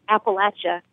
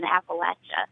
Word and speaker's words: Appalachia: I've never heard anyone from our area, from Appalachia, call it anything other than Appalachia. When I hear someone say Appalachia or Appalachia or however they pronounce it, to me, that signifies that person is not from the Appalachian region.